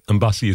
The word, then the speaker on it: Mbassi: Mbassi is spelled M-B-A-S-S-I.